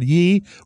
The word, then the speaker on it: Ye: Yeah. Kanye West continues to be wonderful. He's so much fun. He has a new album out called Ye, or as he calls it, Ye. It's spelled Y-E.